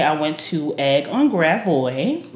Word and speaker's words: Gravois: It's Gravois. Because this is a French ass sound.